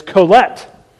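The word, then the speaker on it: Qohelet: So the author is Colette, is what, that's how you pronounce that word.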